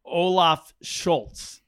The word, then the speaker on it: Olaf Scholz: One of his main competitor is the Social Democratic Party, Olaf Scholz. Am I saying that right? He's the leader of that party. – Mm-hmm. Yeah. – There you go. – Saying it right.